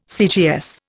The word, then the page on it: CGS: Indian